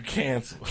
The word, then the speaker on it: Cancel: Like you canceled. Cancel. – Like it's spelled like cancel? – C-A-N-C-E-L.